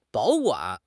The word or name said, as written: 保管